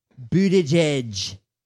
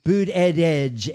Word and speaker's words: Buttigieg: This is, and then you have a young, that Buttigieg, they're saying how to say his name, Buttigieg. – Okay. – Buttigieg, you say boot, and then add edge, boot edge.